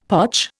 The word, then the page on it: potch: Indian